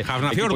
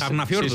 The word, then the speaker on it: Hafnarfjörður: El Sparta eslovaco, el PSV Eindhoven, que es el equipo que cae de la Champions, el Rangers escocés, el Hafnarfjörður, prácticamente impronunciable, Hafnarfjörður, equipo islandés. Hafnarfjörður, sí, sí.